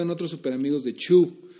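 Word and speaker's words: Chew: Chu, por si no lo escucharon la otra vez, es una historia de Tony Chu Chu se escribe Cheu, como masticar,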